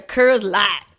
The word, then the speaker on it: Coors Light: And you don't even say that right. It's a Coors Light. That's how you gotta say it.